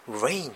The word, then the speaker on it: reign: Confusion 混乱、 Rain っていう動詞、これ雨の Rain と全く発音が同じで R-E-I-G-N という、これ名詞と動詞ありますが、君臨するとか支配するって意味です。